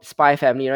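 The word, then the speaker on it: SPY×FAMILY: Um, I believe it's just called Spy Family, right? The X is silent, yeah.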